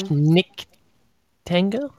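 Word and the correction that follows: Schnittke: Schnitke, yes.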